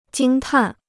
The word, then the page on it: 惊叹: jīng tàn